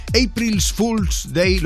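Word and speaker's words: April Fools' Day: es el April's Fool's Day, lo he dicho bien, Marta.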